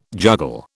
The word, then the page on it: juggle: British English